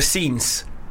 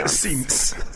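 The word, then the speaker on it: séance: Did you do a scenes? – Yeah, we did a scenes. – A scenes. – Seance.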